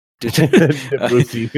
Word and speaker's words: Debussy: Somebody's going to fucking stab me for mispronouncing this name, but Claude Debussy? Debussy? – Debussy.